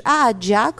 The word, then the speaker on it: Aaja: C'est le fameux, je le cite, « bordel ambiant », très graphique, le « Ahadja » comme on dit en libanais. « Ahadja », ça s'écrit « A-A-J-A ».